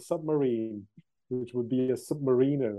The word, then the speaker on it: Submariner: It's actually Submariner and not Submariner. I always say it wrongly. Actually, Bob Barth, when I went to see him in Panama City in Florida, he explained it to me. It's not Submariner.